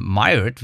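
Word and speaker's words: Máiréad: Das ist die ähm, Myred, wie sie ausgesprochen wird. Myred, Myred ist gar, gar nicht so einfach. Der Name ist nämlich ein irischer Name und den gibt es auch nur da. Das ist eigentlich die, äh, das Äquivalent zu dem äh, englischen Margaret. Und die wird aber Myred, das habe ich extra nachgeguckt, ausgesprochen.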